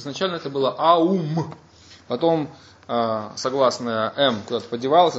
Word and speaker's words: Аум: Он говорит, этот слог «Ом», он более полно произносится как «Аум».